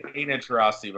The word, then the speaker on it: Taurasi: I forgot how to say her name. Deanna Taurasi. Fuck. She was really good at UConn. – Deanna Taurasi, by the way. – What is it? – Just Deanna Taurasi.